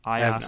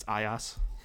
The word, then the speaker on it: iOS: It's iOS. – I have not. I've not updated to the iOS 6. – I'd like to clarify here that anyone who pronounces it IOS is not my friend.